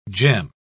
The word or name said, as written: gem